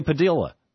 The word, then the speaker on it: Padilla: The most recent, Jose Padilla. We've been saying Padilla all these years. He wants us to know it's pronounced Padilla.